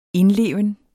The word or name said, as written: indleven